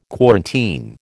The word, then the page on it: quarantine: American English